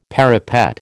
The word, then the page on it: parapet: American English